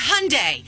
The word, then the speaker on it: Hyundai: And then we found out that Hyundai had a recall. And I said, Mom, it's Hyundai.